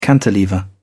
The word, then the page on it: cantilever: British English